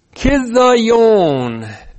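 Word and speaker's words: chizzayon: Kithayon. C-H-I-Z, double Z. Z-Z-A-Y, long O-N.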